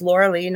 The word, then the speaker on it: Lorilei: Lorilei Potvin. Believe me, my name is spelled weird. It's Lorilei, not Lorelei.